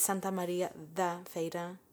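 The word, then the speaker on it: Santa Maria da Feira: Oh, I've been saying it wrong. – Me too. – Okay. – Well, you heard it here first. It's Santa Maria de la Feira.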